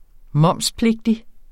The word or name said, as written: momspligtig